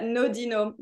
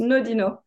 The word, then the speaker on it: Nodinot: No, it's that Nodinot. Just think French, it's flat. There's no accent. It's just Nodinot.